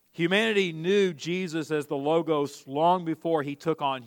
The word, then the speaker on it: Logos: In the Greek, you would hear it pronounced Logos, Logos.